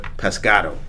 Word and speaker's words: pescado: Not that we're pescado. That's how you say it in Spanish, yes.